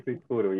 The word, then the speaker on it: Kuikuro: And uh, we have never covered uh, the amazing work that you do with um, indigenous tribes in Brazil, the Kuikura, the Xingu? The Kuikuro, yeah. Yes. – Correct.